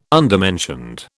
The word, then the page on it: undermentioned: British English